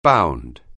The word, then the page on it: bound: anglais